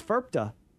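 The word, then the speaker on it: FIRPTA: What's going on with this FERPTA? Am I saying it right? – Yeah, so, yeah, you did.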